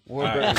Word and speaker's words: Warburton: Warburton. Right. It's a tough name to pronounce. Just break it up into two words. War and Burton, like Richard Burton.